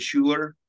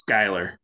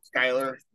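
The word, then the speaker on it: Schuyler: which is Charlie Schuler, right? – I think it's Skyler. – Skyler. – But it's spelled, SCH or whatever.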